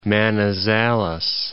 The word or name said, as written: Manizales